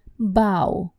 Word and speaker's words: bow: Тут будьте осторожны с произношением, потому что поклон в английском языке произносится как ау, бау, бау.